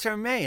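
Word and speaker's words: Hermès: Because you're always asking me, how do you pronounce Hermes? – It's Hermé, isn't it? There's no S, right? – You know what?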